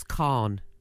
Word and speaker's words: Kahan: K-A-H-A-N. – It's Khan.